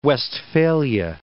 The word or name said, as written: Westphalia